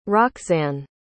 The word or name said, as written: roxane